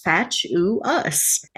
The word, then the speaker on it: fatuous: It's an adjective and it's pronounced fatuous. F-A-T-U-O-U-S.